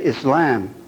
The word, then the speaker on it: Islam: A long a is ah, not, so you would say Islam, not Islam.